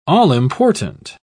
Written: all-important

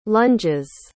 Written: lunges

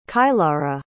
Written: kylara

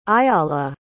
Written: ayalah

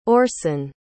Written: orsen

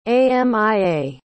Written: amia

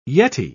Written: yeti

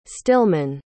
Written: stillman

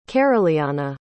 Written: caroliana

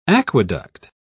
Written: aqueduct

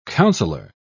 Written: counselor

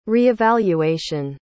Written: reevaluation

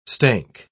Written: stank

